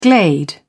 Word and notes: glade: British English

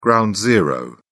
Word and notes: ground zero: British English

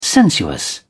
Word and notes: sensuous: British English

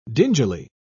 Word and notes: dingily: British English

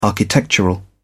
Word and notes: architectural: British English

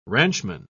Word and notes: ranchman: British English

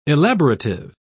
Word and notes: elaborative: British English

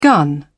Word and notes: gun: British English

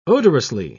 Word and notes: odorously: British English

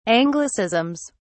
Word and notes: anglicisms: British English

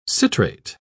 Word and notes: citrate: British English

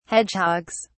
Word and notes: hedgehogs: British English

